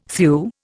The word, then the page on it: thew: Indian